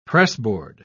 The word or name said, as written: pressboard